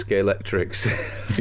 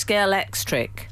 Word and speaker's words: Scalextric: Scalextric. – Yes, it ends with a K. – So it's Scalextric.